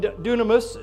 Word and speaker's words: dunamis: And dunamis, it's spelled D-U-N-A-M-I-S, is the miracle power.